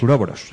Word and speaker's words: Ouroboros: A ver si lo digo bien. Ouroboros. – Los uroboros. – Eso,